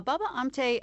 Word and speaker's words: Baba Amte: So tell us about Baba Amta, if I'm saying it correctly. – Yeah, Baba Amte, I think what you're thinking of, why he seems unlikely, is that he was born into a very aristocratic family.